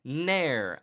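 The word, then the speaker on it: Naeher: We're going to have Ashlyn Harris and Alyssa Nair, as in rhymes with hair.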